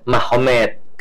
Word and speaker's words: Magomed: Jadi tulisnya Magomed, tapi bacanya Mahomet.